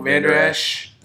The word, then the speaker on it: Vander Esch: Smith and Vander Erch. I don't think I'm saying his name wrong. – Vander, Esch. sorry.